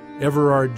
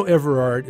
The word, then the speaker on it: Everard: Everard Jones. I know Everard is a strange name, a hard one to pronounce. Everard, A-R-D. Most people want to call me Everett, and it's all the same to me.